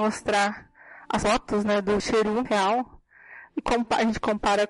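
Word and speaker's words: Sheru: O nome dele não era Saru, era Cheru.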